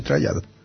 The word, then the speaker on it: trial: O sea, está pronunciado bien, trial.